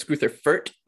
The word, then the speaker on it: Greuther Fürth: It's Greuther Fürth, like Kurt with a K, and Greuther Fürth.